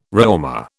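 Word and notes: Réaumur: British English

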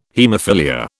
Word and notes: haemophilia: British English

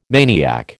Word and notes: maniac: American English

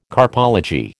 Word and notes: carpology: American English

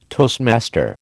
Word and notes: toastmaster: American English

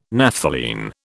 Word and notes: Naphthalene: British English

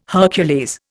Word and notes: Hercules: Indian

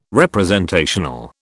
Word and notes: representational: British English

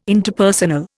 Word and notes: Interpersonal: Indian

